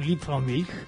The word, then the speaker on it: Liebfraumilch: el famoso Liebfraumilch. Así se dice, así se pronuncia.